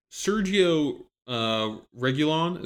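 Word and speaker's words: Sergio Reguilón: Mine's going to be Sergio Reguilon.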